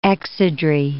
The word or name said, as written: exedrae